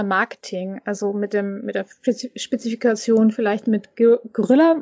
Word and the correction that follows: Guerilla: Guerilla, genau.